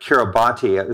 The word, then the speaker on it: Kiribati: And for those who want to look this up, it looks like Kiribati is what I thought the pronunciation was. It's K-I-R-I-B-A-T-I.